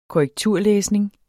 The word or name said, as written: korrekturlæsning